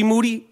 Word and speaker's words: Moody: Moody? He said, yes, last name rhymes with Duty.